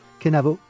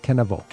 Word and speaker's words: Kenavo: Kenavo. Kenavo.